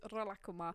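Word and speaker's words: Rilakkuma: Welcome to the show where we just talk about Rila Kuma, or the Japanese pronunciation is Rila Kuma.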